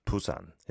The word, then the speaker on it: Busan: Blazing saying, why pronouncing Busan incorrectly? Busan, Busan is how you say it in Korean, right? Busan. It's not Busan or anything like that.